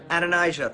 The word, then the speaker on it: Adonijah: Adonijah.